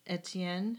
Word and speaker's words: Etienne: Yeah, and I think his name is Edetine. Is that how you would pronounce that? Edetine? – Etienne, if it's French.